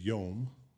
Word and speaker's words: yom: That word is yom. So okay. we would spell that transliterated. It's Y-O-M.